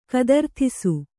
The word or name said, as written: ಕದರ್ಥಿಸು